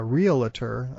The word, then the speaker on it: realtor: Okay, well, the next one I have on the list, the word realtor is spelled R-E-A-L-T-O-R with no extra A between the L and the T there. – Mm-hmm. – But for some reason, in speech, people often put in an extra A, an extra syllable here, so they call it a realtor. I'm not sure where that comes from, but the word is realtor.